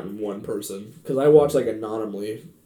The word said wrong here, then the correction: Anomaly: Anomaly, like, for, like, 2 days.